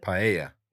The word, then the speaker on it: paella: I got to say, two whiskeys and some paella. Is that how you say it? – Paella. – Paella.